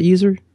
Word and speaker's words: usr: You do you pronounce that user? Yeah, that's very confusing.